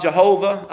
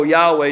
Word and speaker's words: Yahweh: Now, some people want to call him Jehovah. I like to call him Yahweh because I know Yahweh is more correct pronunciation of his name. Jehovah was actually a misspelling from a German scholar.